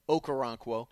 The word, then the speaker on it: Okoronkwo: Ogbonna Oronkwo. I can never say his name. Okoronkwo.